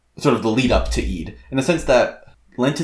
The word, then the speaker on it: Eid: And by the way, I sound like I'm being an a-hole when I say Eid. That is actually how I would pronounce it if I spoke Arabic. Although, there is a glottal stop in Eid, but I can't really mimic that because I'm not Arabic.